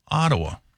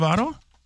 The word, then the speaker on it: Ottawa: Ottawa. – Ottawa? – Yeah. – Okay. – Haven't you heard of Ottawa? – No, I haven't.